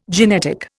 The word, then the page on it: genetic: Indian